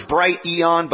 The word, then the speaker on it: Brighteon: It's like two words, Brighteon, but I just say Brighteon.